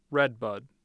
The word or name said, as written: Red Bud